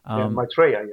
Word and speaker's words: Maitreya: Maitreya. I hope I'm pronouncing it that right. – Maitreya, yes. – Maitreya.